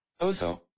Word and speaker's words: Otho: His advisors were terrible, and they shielded him from the reality of all of it, letting the next guy, Otho, I believe it's pronounced Otho, one sec. I have to Google how to pronounce this. – Otho.